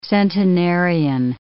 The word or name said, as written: centenarian